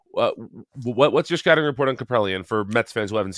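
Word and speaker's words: Kaprielian: The biggest discrepancy right now in terms of performance is the Friday night matchup, which is Senga versus James Caprellian.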